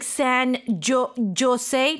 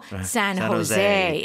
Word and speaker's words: San Jose: And so J is not J, it's Huh. – Right. – Okay. – 네, 그게 허 가끔 그럴 때가 있어요. Yeah. 그 라틴 쪽 관련돼 있는 발음이나 이런 게 J가 허 이렇게 나올 때가 있죠, 그렇죠? – Like San Jose, no, San Jose. It's a J, but it's a H.